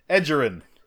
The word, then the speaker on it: Edgerrin: Edgerrin. It's a soft G. – No. – Yes, it's like giraffe, not Gary.